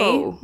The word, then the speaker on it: Tuco: Tuco, form an uneasy partnership. I hope that's how you say his name. I'm sure it's not. – It sounds okay. Tuco.